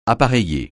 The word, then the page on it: appareiller: francais